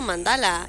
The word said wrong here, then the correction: Mandela: efecto Mandela.